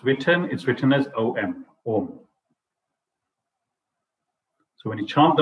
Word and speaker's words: Om: In Sanskrit grammar, A and U coalesce to form O. Thus, A, U, and M together is pronounced as Om.